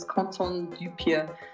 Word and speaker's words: Quentin Dupieux: His real name is, in English you'd say Quentin Dupieux, but in French you pronounce it as Quentin Dupieux.